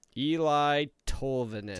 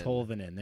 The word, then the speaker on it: Eli Tolvanen: Eli Tolvanen. – Tolvanen, there we go.